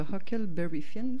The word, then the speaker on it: Huckleberry Finn: Mark Twain, on le connaît surtout pour les aventures de Tom Sawyer et les aventures de Huckleberry Finn, si je le prononce correctement. – Oui.